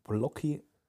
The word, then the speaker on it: Plokhy: Also ich habe äh, das Buch von Seri Blocki schreibt man P-L-O-K-H-Y,